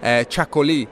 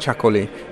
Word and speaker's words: Chacolí: Chacolí, which I think I pronounced Chacolí in the wine podcast. I didn't realize there was an accent on the final I.